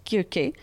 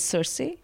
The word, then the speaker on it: Circe: In terms of fiction, I have to say that I recently read Madeline Miller's, I believe in ancient Greek, it's technically pronounced Kirke, though all I've ever heard it pronounced in bookstores and all is Circe, C-I-R-C-E.